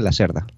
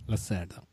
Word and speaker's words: Lacerda: Bueno, una cosa, a mí me dijeron que se pronuncia Lacerda. – Lacerda.